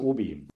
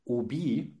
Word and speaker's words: Obi: Der Firmengründer Manfred Maus hat Ende der 60er Jahre bei einer Geschäftsreise in Frankreich erfahren, dass seine Hobby-Marke unter dem Namen Obi, weil nämlich die Franzosen das H ja nicht aussprechen, registriert hatten.